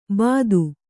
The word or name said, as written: ಭೀತೆ